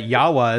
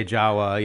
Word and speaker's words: Jawa: I learned that Yawa is the proper way to say Jawa, you know, like, you know.